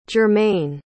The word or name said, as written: jermayne